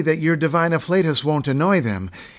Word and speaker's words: afflatus: And there is afflatus, related to inflate and flatulent, and to be pronounced with the same long A, not afflatus, but nearly always seen with divine before it.